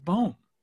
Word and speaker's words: Bohm: When Alec was on that roster, I'm remembering this incorrectly, but I am 100% certain that USA Baseball told us that his last name was Bohm, but it is Bohm, according to the Major League pronunciation guide.